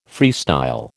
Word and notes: freestyle: American English

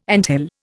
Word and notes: anthill: Indian

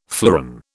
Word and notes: fleuron: British English

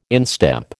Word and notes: instep: American English

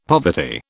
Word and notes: poverty: British English

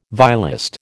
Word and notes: violist: American English

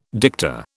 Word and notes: dicta: British English

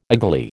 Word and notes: agley: American English

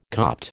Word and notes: Copt: American English

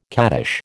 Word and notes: cattish: American English

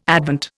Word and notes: Advent: Indian

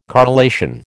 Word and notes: correlation: American English

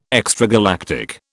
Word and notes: extragalactic: British English

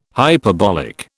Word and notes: hyperbolic: British English

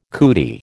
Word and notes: cootie: American English

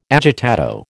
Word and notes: agitato: American English

